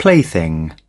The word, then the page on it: plaything: British English